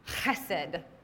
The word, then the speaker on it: Chesed: It has the ch at the beginning. It's not actually a very pretty sound. It's got that guttural, like, reach all the way to the back of your throat to pronounce it kind of sound. Chesed.